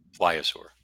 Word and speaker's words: pliosaur: So, and what do you know about how do you pronounce it? Pliosaur? – Pliosaur. – Pliosaur. – Yeah.